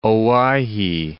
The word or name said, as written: Owyhee